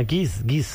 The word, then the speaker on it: Geese: G-E-E-S-E. – Ah, Giz. Como gansos.